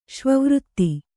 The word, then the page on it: ಶ್ವವೃತ್ತಿ: kannada